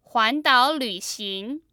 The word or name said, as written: huandaolvxing